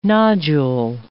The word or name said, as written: nodule